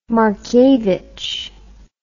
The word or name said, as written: markiewicz